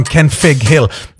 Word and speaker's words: Kenfig: Kenfig Hill. Nope, that's absolutely right. Kenfig Hill with an F. Because the original Welsh is Mynydd Cynfig, which is a double F.